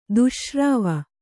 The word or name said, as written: ದುಶ್ಶ್ರವ